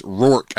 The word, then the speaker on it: Roark: and it's Roark, I guess. People were giving me a hard time for saying it Roark last week, but I know someone whose last name is pronounced Roark that has spelled that same way, so I guess it's Tanner Roark.